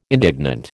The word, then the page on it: indignant: American English